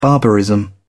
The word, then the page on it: barbarism: British English